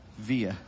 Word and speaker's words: Villa: Villa. Take those L's and knock them down.